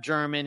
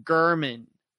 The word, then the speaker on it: German: And I got his name wrong, even though it looks like Jeff German. It's actually Jeff Gurman. So you pronounce it G-U-H-R-M-A-N-N, even though it looks like German with an N on the end.